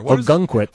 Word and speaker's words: Ogunquit: Ogunquit.